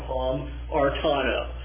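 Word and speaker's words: hamartano (ἁμαρτάνω): That's how it would be pronounced, hammer, hamartano.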